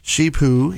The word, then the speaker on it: Shih Tzu: She is an 11-week-old Shih Tzu. – I can't say it right. – Shih Tzu, yep.